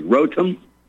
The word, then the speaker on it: Rotem: And that's spelled R-O-T as in Tom, E-M as in Mary. Rotem Galati, G-I-L-A-D as in David, I as in Ivy.